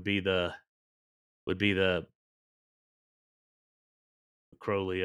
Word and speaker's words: Crowley: Crowley, Crowley, right. How you say it. And yeah. I think Ozzy Osbourne screwed it up. It, it's Crowley.